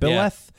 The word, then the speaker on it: Byleth: Byleth.